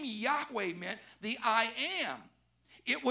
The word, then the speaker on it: Yahweh: the name by which he went was we now pronounce Yahweh, but for many, many, many, many years, people thought that the Hebrew pronunciation was Jehovah. How many ever heard the name Jehovah? Well, there's no such Hebrew word. The, the Hebrew doesn't even have a J sound.